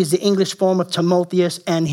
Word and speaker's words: Timotheus: Timotheus is how you pronounce it, but he's called a true son of the faith.